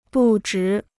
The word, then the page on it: 不值: bù zhí